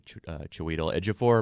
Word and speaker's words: Chiwetel Ejiofor: I mean, you have the persecuted Solomon Northup, played by Chiwetel Ejiofor.